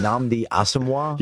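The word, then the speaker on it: Nnamdi Asomugha: Nnamdi Asamoah. I couldn't do it till a couple of days ago. Is it Nnamdi Asamoah? – Yeah, it is what it is.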